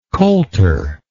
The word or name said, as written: coulter